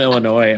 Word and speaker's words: Illinois: I'm from Illinois. And when people pronounce the S at the end, it drives me nuts.